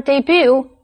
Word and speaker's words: debut: computer 这个字的重音在第二个音节。或者是 when you say the word debut, accent the last syllable.